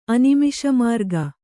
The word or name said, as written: ಅನಿಮಿಷಮಾರ್ಗ